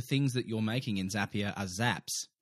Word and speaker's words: Zapier: It's Zapier because Zapier makes you happier.